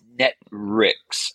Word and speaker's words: Netwrix: So actually, I think it's Netrix. I think it's NETWRIX, I think. But on how to pronounce that exactly is probably anyone's guess, I would say, except for the folks who know how to pronounce it.